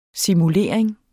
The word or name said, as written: simulering